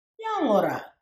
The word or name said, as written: Yangoorra